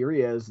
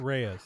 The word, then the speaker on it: Reyes: Keep in mind, that's right, Ravi Reyes, not the Ghost Rider that you know of. – Reyes. – Reyes. Pardon me. – Reyes. – That last name is pronounced Reyes.